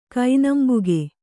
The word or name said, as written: ಕೈನಂಬುಗೆ